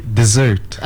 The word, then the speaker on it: dessert: Well, the correct way to say it is actually dessert. – Okay. – Okay. – And you have to stress on the S. That's why it has a double S.